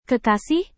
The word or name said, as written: kekasih